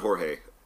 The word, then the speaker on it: Jorge: I don't know if it's Jorge or George. – They pronounce it Jorge.